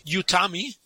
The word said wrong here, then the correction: Yutani: Mhm. – Hm, Wayland Yutani, ja.